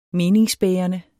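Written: meningsbærende

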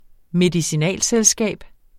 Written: medicinalselskab

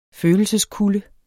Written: følelseskulde

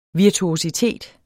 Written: virtuositet

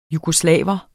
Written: jugoslav

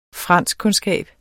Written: franskkundskab

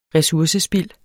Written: resursespild

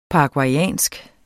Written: paraguaysk

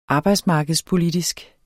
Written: arbejdsmarkedspolitisk

